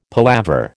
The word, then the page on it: palaver: American English